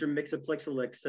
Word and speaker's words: Mxyzptlk: Try and get them to say Mr. Mxyzptlk and they're, uh, and they won't uh, make it, you know? You actually said it right.